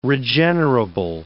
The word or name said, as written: regenerable